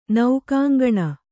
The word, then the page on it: ನೌಕಾಂಗಣ: Kannada